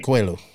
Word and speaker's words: Coelho: was yeah Coelho. Coelho said it right.